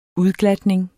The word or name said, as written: UDGLATNING